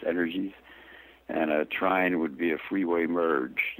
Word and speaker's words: trine: Trine, is it? – Trine.